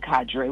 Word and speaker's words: cadre: So I know that some of us say cadre, which is probably allowed, but I think the one you use is a Cambridge Dictionary pronunciation, which is sort of what we look to.